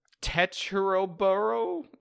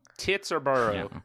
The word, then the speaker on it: Teterboro: Somebody tell me how to pronounce this proper noun. – Proper noun. Teterboro. – Teterboro? – Teterboro. Teterboro. – Teterboro. – Tits or burrow.